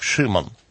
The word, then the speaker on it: Шимон: Значит, еврейское имя Петра – Симон, ну, или на самом деле это скорее произносится как Шимон.